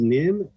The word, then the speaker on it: Nhim: I mean, it's spelled N-H-I-M. Who is this person? – That is Nim.